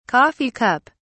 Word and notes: coffee cup: British English

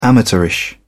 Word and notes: amateurish: British English

